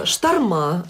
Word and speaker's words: шторма: Вот скажите, пожалуйста, почему моряки ставят ударение не там, где привычнее всем остальным людям? Шторма, они говорят, ветра, говорят. Ну, что еще? Мурманск, классика жанра.